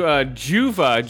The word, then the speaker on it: Juva: So I went to Juva, J-U-V-A, in my local town. So when I walk in there. – That's how it's pronounced. – Juva. Yeah, J-U-V-A. You can look up their shit online.